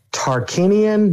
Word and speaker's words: Tarkinian: So the new one I had was the, I want to make sure I say this right, the Tarkinian night flower. Am I saying that right, Al? You are.